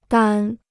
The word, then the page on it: 单: dān